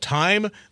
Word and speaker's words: Thyme: It's, as you mentioned, it's called Time. That is spelled the same as the herb, T-H-Y-M-E, Time for Bed Dome.